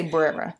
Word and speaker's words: Bwera: The child has since been treated for injuries on his hand at the Brera Hospital in West Uganda. It's spelled B-W-E-R-A. Brera. I would say Brera.